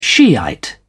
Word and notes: shiite: British English

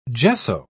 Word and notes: gesso: British English

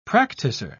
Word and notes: practicer: British English

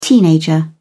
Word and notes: teenager: British English

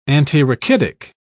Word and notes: antirachitic: British English

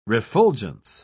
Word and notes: refulgence: British English

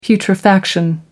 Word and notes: putrefaction: American English

